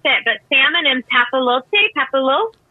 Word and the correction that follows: papillote: papillote, papillote, papillote with seasonal vegetable ribbon.